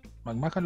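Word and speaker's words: McMahan: que es esta entrevista a McMahon. McMahon lo dije bien, sí.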